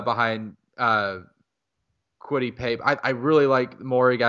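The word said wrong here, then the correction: Moehrig: out of, am I saying it right? Merrig.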